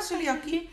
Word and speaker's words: celiakie: Je hebt dat bij celiakie, dat is een intolerantie of allergie tegen gluten. Zeg ik dat goed? – Celiakie? – Ja, celiakie. Daar is het bekend dat het de schildklier daar heel nauw mee verbonden is.